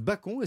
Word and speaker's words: bacon: Non, il est français et on disait bacon. – Ça se prononce bacon et ça désignait autrefois en France le porc de manière générale.